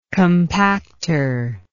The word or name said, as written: compactor